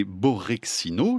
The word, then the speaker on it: Borexino: Vous faites tous les deux partie de cette collaboration internationale qui a travaillé donc sur une expérience appelée Borexino, j'espère que je le prononce bien. – Oui, c'est correct.